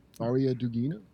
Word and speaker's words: Daria Dugina: Most recently, Dara Dugina. How do you say that name? – Daria Dugina.